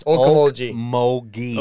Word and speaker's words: Okmulgee: Okmulgee. – Hey. Sometimes a G can make a J sound.